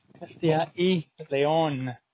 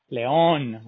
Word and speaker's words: Castilla y León: Another sunny day here in beautiful Castilla León. Castilla y León. León, with an accent on the O. León.